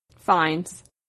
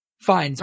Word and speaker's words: Fiennes: Fiennes. – Fiennes.